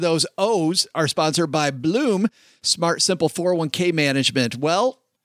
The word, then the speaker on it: Blooom: With Bloom, I don't say it right. Say it, Len. – Bloom. – With Bloom, you can simply connect your existing 401k in a few easy steps.